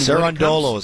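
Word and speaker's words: Cerúndolo: And somehow, some way, as a 32-1 underdog, Cirundolo, I believe is how you say his name, beats Sinner. I mean, Cirundolo is how you say his name.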